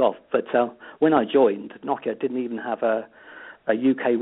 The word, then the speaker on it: Nokia: I then moved on to Motorola for a short time and then ended up at Nokia, depending on how you want to pronounce it. – To the US here, we call it Nokia, yeah.